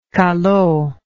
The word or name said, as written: callot